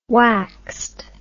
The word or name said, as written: waxed